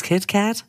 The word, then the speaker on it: KitKat: Also dieses Kit Kat, Kit Kat, wie wird es eigentlich ausgesprochen? Das ist Kit Kat.